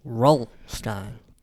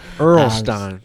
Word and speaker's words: R.L. Stine: And in fact, I thought his name was pronounced Roll Stein. – Roll? – Yeah, R.L. That's how you pronounce it, right? – Earl. Earl Stine. – Yeah, Earl Stine.